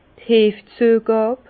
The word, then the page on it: d' Heefzüegàb: Bas-Rhin; Herrlisheim